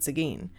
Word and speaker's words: Seguin: So we're going to pronounce it Seguin. S-E-G-U-I-N.